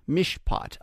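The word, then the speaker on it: Mishpat (מִשְׁפָּט): There's a Hebrew word called Mishpat. Mishpat.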